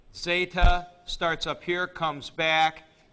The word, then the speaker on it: zeta: Zeta is pronounced with a D, Z, zeta, z. Um, as a matter of fact, in Athens, at uh, uh, classical Greek times, they probably didn't pronounce it that way. They probably pronounced it just the opposite ZD, zd, like this.